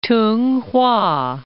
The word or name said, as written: Tunghwa